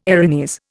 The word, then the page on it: Erinys: Indian